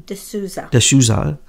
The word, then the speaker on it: D'Souza: D'Souza.